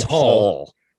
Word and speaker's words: Hall: I mean, they call him Hall. It's like Hall, but with like a short A.